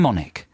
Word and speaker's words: Monick: And it's got a huge article all about this guy called Tim Monkin. Monick? No, Tim Monick. I said that totally wrong. Tim Monick, yeah? Rhymes with gin and tonic.